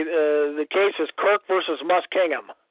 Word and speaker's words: Muskingum: M-U-S-K-I-N-G. – So it's Kirk versus Musking? – Yeah, Muskingum, then U-M. I'm sorry, U-M.